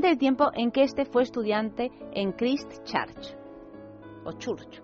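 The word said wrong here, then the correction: Christ Church: ¿cómo se dice? – Christchurch. Eso, gracias, Max.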